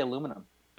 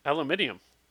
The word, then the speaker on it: aluminium: How do you say aluminum? – Aluminium. How does anyone else say it? – What about laboratory? – I also say aluminium.